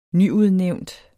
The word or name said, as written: nyudnævnt